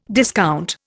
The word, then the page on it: discount: Indian